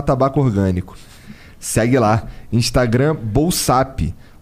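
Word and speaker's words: Bolsapp: O Bolsap, que se escreve assim, ó, B O L S A P P, é bolsa com dois P's no final.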